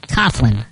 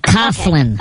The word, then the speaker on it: Coughlin: Coughlin. – Coughlin.